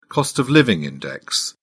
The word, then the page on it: cost-of-living index: British English